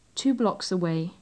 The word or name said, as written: two blocks away